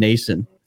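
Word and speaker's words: Stefan Noesen: We ended up getting a fourth round pick for retaining some salary on Foligno and giving up Stefan Nason. Like how I pronounced that name there, Nick? – That was perfect.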